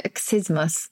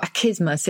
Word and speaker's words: accismus: Well, because this word, achismus, or achismus, if you, I think it, you can pronounce it either way probably now, A-C-C-I-S-M-U-S, and it's the insincere refusal of something you really want.